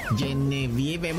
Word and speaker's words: Genevieve: Y la autoridad hizo ojo de hormiga porque mira, se les vino encima esto del Genive, ¿verdad? ¿O cómo se dice Genevieve? Genevieve, bueno, el huracán categoría 3 que les está pasando por un lado, yo creo.